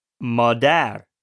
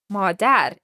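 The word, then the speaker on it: Madar (مادر): Madar. – Madar.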